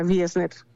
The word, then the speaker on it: Wiesner: Sí, se escribe con W, pero se puede pronunciar como una V pequeña, o sea, Wiesner.